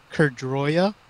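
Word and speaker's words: Caer Droia: It's Cerdroya. It's a hard C because it's like a Welsh word, Oh.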